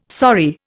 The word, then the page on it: sorry: Indian